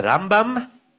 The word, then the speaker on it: Rambam: So he would say that the way you distinguish it is that which syllable you stress, it's the Rambam and the Ramban. So this is the Ramban.